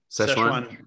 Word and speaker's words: Sichuan: Which, how do you say Sichuan chili oil? – Szechuan. Yeah. – It's spelled different than the Szechuan though. That's S-Z-E, isn't it? This is S-I-C-H. So maybe it's similar.